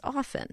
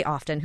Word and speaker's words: often: He said, you don't pronounce the T in often, it's often. And then I felt so vindicated to read your book because some people do say often.